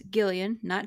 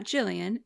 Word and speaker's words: Gillian: Yes, Gillian, not Jillian.